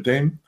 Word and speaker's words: Notre Dame: So Chris McDaniel, big philosopher working at the moment at Notre Dame, or Notre Dame, however you pronounce it. – We say Dame over here. We're American. – Dame. I've got to pronounce things better. So Notre Dame.